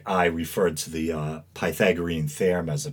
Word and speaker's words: Pythagorean: And the person I was talking to, you know, kind of as nicely as possible, let me know that the proper pronunciation was Pythagorean.